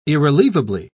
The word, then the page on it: irrelievable: British English